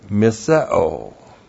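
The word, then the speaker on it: Miseo (μισέω): 1 John 3:15, anyone who hates his fellow believer, and the word for hate in our passage is miseo. M-I-S-E long O. Miseo.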